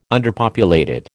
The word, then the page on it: underpopulated: American English